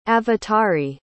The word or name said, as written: avatari